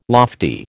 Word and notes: lofty: American English

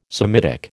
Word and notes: Semitic: American English

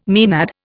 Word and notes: maenad: Indian

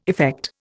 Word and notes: effect: Indian

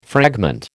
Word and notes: fragment: American English

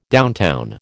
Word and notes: downtown: American English

